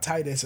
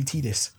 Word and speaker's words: Tidus: That's like people trying to tell me that, you know, in Final Fantasy X, it's Tidus, not Titus, and I will still call him Titus to this day because I hate the name Tidus. – It is Tidus.